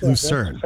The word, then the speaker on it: Lucerne: Is it Lucerne, Switzerland? Am I saying that correctly? – Lucerne, I think. – Yep, Lucerne.